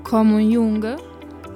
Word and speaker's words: Ko Moon-young: Sì, quindi lo porta, suppongo, a una presentazione del libro, del suo ultimo libro, della la ragazza che si chiama Ko Moon-young. – Sì. – È giusta la pronuncia? Più o meno? – Sì, sì.